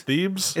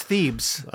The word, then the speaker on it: Thebes: I don't even know how to pronounce that. – Thebes. It's Thebes.